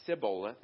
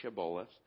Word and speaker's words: Shibboleth: And he, and he said Shibboleth, for he could not pronounce it right, Sibboleth instead of Shibboleth.